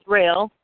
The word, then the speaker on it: Roehl: And it's actually going to be R-O-E-H-L. – I'm sorry. – It's okay, Glenn, but it's R-O-E-H-L. Rail is a family business. It is pronounced rail.